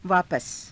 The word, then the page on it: vaapasa: Hindi